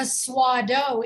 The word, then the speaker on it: Swado: But tell us specifically what kind of swaddle the Swado is, which of those categories it fits in there.